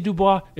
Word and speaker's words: Du Bois: Also, it's Du Bois. It is. I know. Don't email me. Don't mispronounce it to your professor. It's Du Bois. I know you want to say Dubois. It's not that.